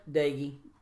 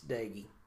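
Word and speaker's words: Doege: So you got Doge, is that how you pronounce his last name? Your quarterback? – Daigie. – There's an O in there. – It's Daigie. – Okay, Daigie. Even though it's D-O-E, it's Doge. – It's Daigie.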